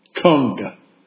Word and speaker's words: Khng: It's spelled K-H-N-G. K-H-N-G. Would anyone here like to pronounce that word for me? K-H-N-G? Kung. Kung. That's the way he pronounced it.